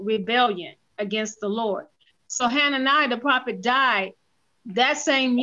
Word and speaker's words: Hananiah: is it Hananiah? How do you pronounce that? Hananiah? Okay, Hananiah, the prophet, hear now, Hananiah, the Lord has not sent thee, but thou makest this people to trust in a lie.